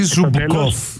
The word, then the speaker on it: Ζούμπκοφ: Και τι άλλο, και Ζούμπκοφ, αυτό δεν το έλεγα για σένα. – Ή Ζούμπκοφ, θέλω να τα προφέρεις τα ουκρανικά σωστά, ε.